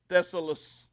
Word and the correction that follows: Thessalonica: Joe Biggs, how do you say that? Joe don't even know. Thessalonica, whatever she said.